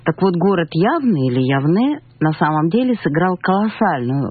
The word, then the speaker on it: Явне: И, как я вам обещала, это будет урок, в котором будем говорить о городе Явны. Или, как положено его произносить, вообще Явне.